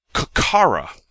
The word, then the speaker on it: Kokkara: It is a kokara. Kokara is spelled K-O-K-K-A-R-A.